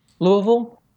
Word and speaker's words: Louisville: That was really upsetting, was that we were saying Louisville and not Louisville. So you got to put it way down there. It's got to be more deep in your throat.